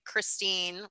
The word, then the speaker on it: Christine: My IG handle is at Lindsay, and my first name is spelled LYNSAY Christine, which is my middle name, CHRISTINE.